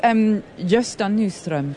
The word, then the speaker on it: Gösta Nyström: So this is Gösta Nyström. Did I pronounce that correctly? – Perfectly.